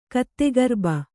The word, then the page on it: ಕತ್ತೆಗಿರುಬ: kattegiruba